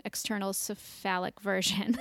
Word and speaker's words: external cephalic version: external cephalic version.